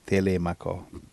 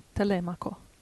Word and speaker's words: Telemaco: in italiano si dice Telemaco. – Telemaco. – Sì, bisogna spostare l'accento.